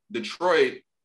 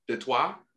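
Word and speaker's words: Détroit: And fun fact, I don't know if you know, the Detroit French, I think it's pronounced Detroit.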